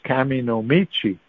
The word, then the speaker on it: Kami no Michi: Shinto is Chinese pronunciation of the Japanese characters. – Yeah. – So but the Japanese pronunciation is Kami no Michi, and Kami no Michi means the way of the gods.